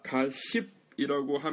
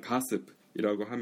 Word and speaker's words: gossip: 아무튼 가십이라고 하면 안 돼요. H 발음 이렇게 나면 안 돼요. 그래서 가습, 그러니까 한국에서 쉽게 볼 수 있는 가습기처럼 그냥 가습이라고 하면 돼요.